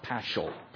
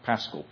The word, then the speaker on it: Paschal: This is, when you read the word Paschal, it's not Paschal, Paschal, P-A-S-C-H-A-L, hard C-H means Passover, stanza four, lays means hymns.